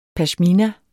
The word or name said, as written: PASHMINA